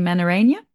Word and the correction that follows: menorrhagia: Did I pronounce that right? – Menorrhagia, so it's just like bleeding. So it's, yeah, it's like excessive bleeding. So it's meant to be like, you know, heavy menstrual bleeding, but when it goes like beyond the time frame for your menstrual cycle, when it's really heavy, when it's going on for weeks, when it's going on for months, you know, that's when you get diagnosed with menorrhagia. Yeah. – Okay. Sorry, I pronounced it completely wrong.